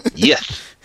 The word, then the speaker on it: Yith: Yith.